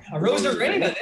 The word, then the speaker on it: Arozarena: How do we say it? Arozarenka? The stud? – Arozarena? – Arozarena. There he is.